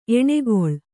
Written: ಎಣ್ಗೊಳ

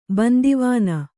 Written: ಬಂದಿವನೆ

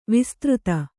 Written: ವಿಸ್ತøತಿ

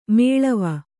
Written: ಮೇಳಾವ

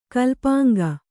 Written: ಕಲ್ಪಾಂಗ

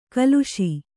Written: ಕಲುಷ

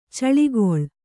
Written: ಚಿಲುಕೂಲಿ

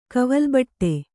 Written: ಕವಲ್ಬಟ್ಟೆ

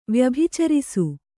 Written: ವ್ಯಭಿಚರಿಸು

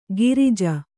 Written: ಗಿರಿಜ

